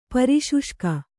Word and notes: ಪರಿಶುಷ್ಕ: pari śuṣka

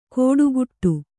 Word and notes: ಕೋಡುಕುಟ್ಟು: Kannada

